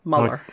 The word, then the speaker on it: Mueller: Mueller. – My tongue Mueller. – Just won't do it. – Just forget the E even exists.